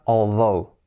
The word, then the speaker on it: although: Tenemos también although, escrito although, cuidado porque se escribe although y se pronuncia although, así sería although. Tiene esa th, que no es although, no.